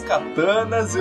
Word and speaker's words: katana: Eu tirei as minhas katanas, eu fui pro. Enfim, eu sei que tá errado, que é katana a pronúncia, Ai, mas não Deus.